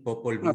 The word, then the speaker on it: Popol Vuh: Tanto para los judio-cristianos, la palabra, el verbo hizo, simplemente decir algo, hizo al mundo, también en el Popol Vuh vemos eso. ¿Lo pronuncio bien? – Sí, Popol Vuh.